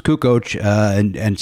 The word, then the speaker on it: Kukoc: No, no, Kukoc is the way that it is. But people would go, "Yeah, so I'm watching the game last night and Kukic." Nope, they, he, he, you're talking to him. Hey, Toni Kukic. Oh, it's pronounced Kukoc.